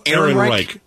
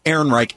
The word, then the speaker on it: Ehrenreich: And this is played, I cannot pronounce, Alden Ehrenreich. – Yeah. Ehrenreich.